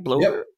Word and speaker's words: Bloober: So Konami, it's been announced recently that Konami is partnering with Bloober, am I saying that right? – Bloober, yeah.